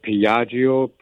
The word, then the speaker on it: Piaggio: And if I pronounce this right, Piaggio, P-I-A-G-G-I-O.